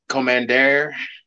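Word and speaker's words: Kommander: Commander, or Justin, how do you say his name? You say his name. You say it so well. – Commander.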